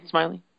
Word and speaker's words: Smyly: I think is that how you say his last name? – Yep. – That's Smiley. What I thought. It's not spelled the same as Wade Smyly. So I just wasn't sure, but, or whatever. But yeah, it's Smyly. – Yes, it is Smyly.